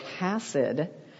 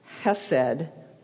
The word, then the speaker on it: hesed: This could be pronounced, but you've heard Bob say it, hasid. It also can be pronounced hased or hasad. And so any of these pronunciations, if you hear us say it, it's this word and it's not wrong. The root of this word is pronounced hesed with a little kind of a roll in the back of your throat.